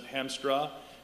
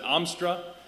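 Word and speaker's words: Haemstra: Home of Zondervan Publishing, Furniture City, USA, and so being from up north, it's a very, very Dutch community, and my grandmother goes crazy when people pronounce it Hamstra or Hamster. Oh, not that you'd be in trouble with her. She pronounces it Amstra, and they call me Johnny, but you guys can't call me that.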